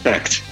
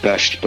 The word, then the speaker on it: Becht: Yes, Becht, yeah. Although it is in some parts of Germany pronounced Becht, but I won't go into semantics there. No, right. Becht is the way it's been pronounced.